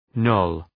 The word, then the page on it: knoll: nəʋl